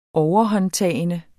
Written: overhåndtagende

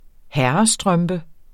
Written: herrestrømpe

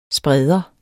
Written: SPREDERE

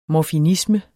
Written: morfinisme

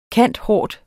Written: cand.hort.

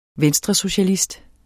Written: VENSTRESOCIALIST

